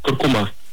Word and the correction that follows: куркума: Несмотря на то, что большинство произносит данное слово с ударением на последний слог, акцент в нем все же стоит на втором. Правильно говорить куркума, представляете?